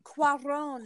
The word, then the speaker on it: Cuarón: Cuarón.